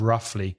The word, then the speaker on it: roughly: Be careful of the pronunciation of roughly. The spelling is R-O-U-G-H-L-Y, but the pronunciation roughly.